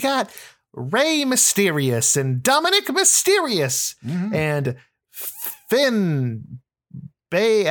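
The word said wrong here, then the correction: Bálor: How do you pronounce the A with the accent aigu? – Finn Balor.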